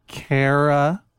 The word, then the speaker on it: Kara: You may be seated, Aaron and Kara, spelled K-A-R-A, but pronounced Kara.